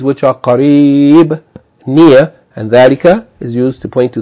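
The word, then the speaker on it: Dhalika (ذلك): Note, it says dhalika is pronounced as though there's an alif written after the letter tha, thal. Tha, fatha, alif. There's no alif written, but you have to pronounce it as though there's an alif written for elongation. Dhalika.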